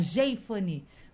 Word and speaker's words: Jeifane: Jeifane, como se a gente colocasse um acento, né?